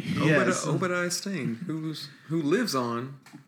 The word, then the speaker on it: Obadiah Stane: Obadiah Stane. Obadiah Stane. I always say like like Obadiah Strain or Stobadiah Lane or something, but Obadiah oh.